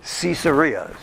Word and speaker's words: Caesarea: Caesarea is what you said, right? Deb, is the correct pronunciation.